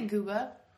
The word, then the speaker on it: Guba: G-U-B-A. How would you pronounce that? – I would say Gooba. Yeah, because it's like Cuba.